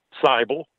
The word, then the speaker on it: Seibel: Seibel. Yeah, rhymes with Bible.